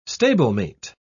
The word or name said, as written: stablemate